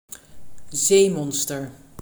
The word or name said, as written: zeemonster